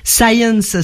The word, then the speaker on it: Sayens: Science, ça s'écrit, ça se dit comme la science en anglais, mais ça s'écrit S-A-Y-E-N-S.